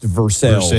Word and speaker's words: Versailles: It's like Versailles being pronounced Versailles in Kentucky.